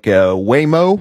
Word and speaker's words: Waymo: They got considering legislation right now for legislation that would create a permit system for commercial driverless passenger vehicles, including robo-taxis like Waymo.